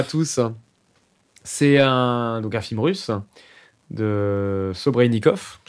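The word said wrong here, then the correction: Serebrennikov: je sais pas si je le dis bien. Ouais, Kirill non, Serebrenikov. Serebrenikov, hein, qui est un ennemi de Poutine, d'ailleurs, on peut le dire.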